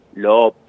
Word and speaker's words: loop: Si los chicos y los estudiantes, los que nos están escuchando, buscan, investigan esta palabra, loop, que se escribe L-O-O-P, loop, loop. Si investigan un poquito, seguramente van a llegar a encontrar alguna herramienta para poder emular lo que está haciendo Juana, ¿no?